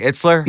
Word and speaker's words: Itzler: Itzler? – Yeah.